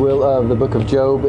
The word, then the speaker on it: Job: Not Job, Job. It's a long O.